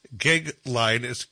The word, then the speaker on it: Goeglein: It's Timothy, and his last name's Gegline, and it's spelled G-O-E-G-L-E-I-N.